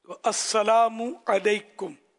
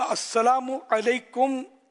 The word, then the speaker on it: السلام علیکم: السلام علیکم، السلام علیکم، السلام علیکم، السلام علیکم، اچھا میم پر پیش ہے، بعض لکھنے والے میم پر واو لکھتے ہیں،